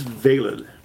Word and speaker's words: Veilid: I have this thing because I've been running Linux for so long that like everything that ends in a D now, you have to pronounce the D. And so it's almost Veilid in my mind.